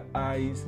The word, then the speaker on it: Ides: In this year, in the year 44, before Common Era, Julius Caesar was assassinated in the eyes of March. And what is the meaning of eyes? This word, this term, took my attention, so I did a little research for you. Eyes, that is spelled I-D-E-S, has the meaning of the day that fall roughly in the middle of a month in the ancient Rome.